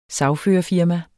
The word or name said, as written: sagførerfirma